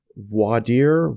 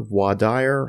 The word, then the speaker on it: voir dire: And I want you to talk a little bit about, and I'm going to pronounce this correct, voir dire, voir dire.